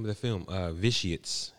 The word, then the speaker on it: Vitiates: Nothing the way it sounds or spelled. – It's a weird title, but once it catch on, you'll catch on. Vitiates. – Vitiates. Yeah. Spell it. – V-I-T-I-A-T-E-S. – Okay.